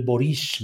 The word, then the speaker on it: Boric: Gabriel Boric,